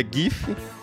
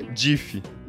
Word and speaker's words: GIF: Você pronuncia GIF ou você pronuncia DIF? – Não, quem, quem fala DIF é um idiota.